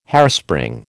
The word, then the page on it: hairspring: American English